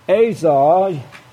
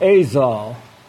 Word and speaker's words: Azov: Just above it here is that Sea of Azov. It's A-Z-O-Y. We continue to monitor the situation with merchants' traffic in the Sea of Azov. Now, they spell it A-Z-O-V here, said the lieutenant.